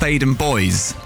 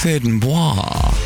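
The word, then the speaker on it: Theydon Bois: There's a place in Essex that I grew up near, which is pronounced Thaden Boys, okay? Thaden Boys, stupid name anyway. But the boys bit of it is spelt B-O-I-S. If we're being French about it, it's Thaden Bois.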